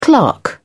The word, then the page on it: clerk: British English